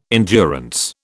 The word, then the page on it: endurance: British English